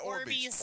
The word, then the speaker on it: Orbeez: Oh, the Orbeez? – Yeah. Is that how you say it? Orbeez.